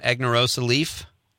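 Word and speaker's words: Aganorsa Leaf: Am I saying that right, Norm? Agnerosa Leaf? – Perfect. – Okay. – Yeah, you got it spot on.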